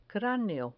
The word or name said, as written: craneo